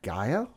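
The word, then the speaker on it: G'iah: Gaia, I think is how you say it. – Yeah. – G apostrophe I-A-H.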